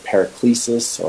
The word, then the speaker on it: paraklesis: The word exhortation is paraklesis, and I've always pronounced it paraklesis or paraclete,